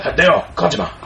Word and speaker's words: Kojima: Kojima, how do you pronounce that? – Hideo Kojima.